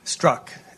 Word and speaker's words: Strzok: And I'm not sure I pronounced the name correctly. – Strzok. – Thank you.